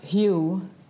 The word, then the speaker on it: Hu: Det uttalas Hu men stavas H-U, Hu, och det är ett gammalt namn för Gud, och det kommer från Ekankar, religionen om själsfärder.